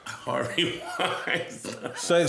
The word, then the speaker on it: Harvey Weinstein: Harvey Weinstein.